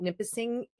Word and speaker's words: Nipissing: Nipissing University.